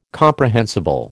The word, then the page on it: comprehensible: American English